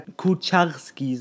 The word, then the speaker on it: Kucharski: In dieser Woche kam jedoch dann auf, dass er sich angeblich von seinem Berater Kucharski, so wird er richtig ausgesprochen, tut mir leid, ich bin im Polnisch nicht so ganz fließend.